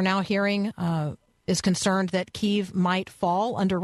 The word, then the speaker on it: Kyiv: Missiles struck the capital city of Kiev or Kyiv this morning, and both pronunciations are just fine.